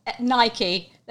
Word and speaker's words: Nike: It is actually pronounced Nike, and that is the correct.